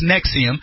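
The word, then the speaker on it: NXIVM: N-V-I-X-M or something like that, or N-X-V-I-M or whatever it is, but it's pronounced Nexium.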